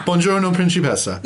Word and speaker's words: Buongiorno, Principessa: Buongiorno, Principessa.